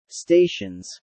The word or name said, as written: stations